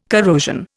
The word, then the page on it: corrosion: Indian